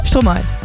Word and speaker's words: Stromae: Stromae. – Stromae, sorry. Ja, dat is natuurlijk. – Ja. Geef niet. – Met Tove Lo. Ja. Dit is een electropop track van Tove Lo in samenwerking met Stromae.